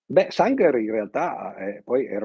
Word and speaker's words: Sanger: Sanger, Dice, sì, sì. Sanger,